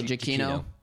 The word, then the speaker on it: Giacchino: Oh, Giacchino. – Michael Giacchino. – Yeah, Giacchino.